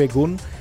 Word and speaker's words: Egun: Egon o Egun, también me comentaba Chris que se pronuncia así tal cual como se escribe, Egun.